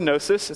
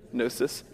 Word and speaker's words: gnosis: And it comes from this word gnosis. It's a Greek word. Can everyone say gnosis? Gnosis. You don't pronounce the G. It's not gnosis.